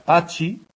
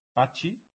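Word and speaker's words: Patxi: La X se pronuncia como la Che francesa. Pachi, Pachi, con TX, ¿de acuerdo? Y la TX como la Che castellana.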